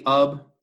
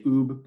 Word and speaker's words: Ub: I say Ub. I know some people say Oob, but I always go with Ub.